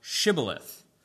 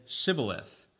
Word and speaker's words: Shibboleth: If he said, nay, no, then said they unto him, say now Shibboleth. And he said, Sibboleth.